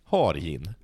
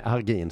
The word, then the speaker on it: Hargin: H-A-R-G-I-N. – Hargin. Nej, Hargin skulle jag säga. Hargin.